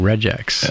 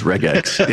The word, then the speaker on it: regex: My entire life, I've been saying regex. – Yeah. And you've been wrong. – It's regex. Yeah. – Well, it's regex.